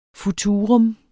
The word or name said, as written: futurum